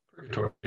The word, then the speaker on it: Purgatory: How do you say it in English? Purgatory. Purgatory. Okay.